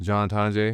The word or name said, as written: John Tonje